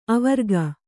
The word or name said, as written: ಆವರಕ